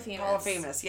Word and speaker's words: Polyphemus: That's like when he's yelling to Polyphemus or... Am I even fucking saying it right? – Polyphemus, yeah.